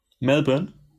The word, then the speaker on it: Melbourne: Fun Fact: Melbourne. Übrigens heißt es Melbourne und nicht Melbourne oder Melbourne oder Melbourne. Das er, er, er spricht man anscheinend nicht aus.